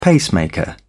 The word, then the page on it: pacemaker: British English